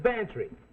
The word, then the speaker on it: Bantreagh: Sounds like Cloud, but it's spelled M-A-C-L-E-O-D. Well, his is Bantry. Go on, spell it. Pantry, it sounds like pantry. – I ain't no gambler, Mac. – Well, get this, for free. It sounds like pantry, but it's spelled B-A-N-T-R-E-A-G-H.